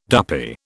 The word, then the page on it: duppy: British English